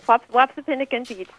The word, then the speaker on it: Wapsipinicon: How do you say that? – You said it, Wapsipinicon peach.